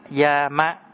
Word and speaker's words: Yama: Tiếng Bali đọc là Yama, Yama.